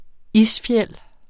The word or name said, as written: isfjeld